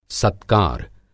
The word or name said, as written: सत्कार